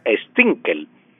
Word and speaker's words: Stunkel: Y los Stinkel, se escribe Stunkel, pero se pronuncia Stinkel.